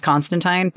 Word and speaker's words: Constantine: Which, by the way, I know that we all learned with the release of Sandman that we've been mispronouncing Constantine our entire lives. It's supposed to be pronounced Constantine, but it sounds wrong to me. I don't care how I'm supposed to pronounce it. I'm saying it Constantine.